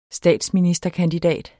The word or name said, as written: statsministerkandidat